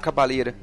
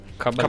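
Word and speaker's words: Caballé: que ele gravou um disco com a Monserrat Cabaleira. Cabalé.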